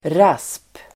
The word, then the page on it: rasp: [ras:p]